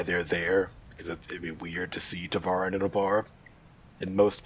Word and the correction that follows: Tevarin: I believe it's pronounced Tevarin. Tevarin is the pronunciation.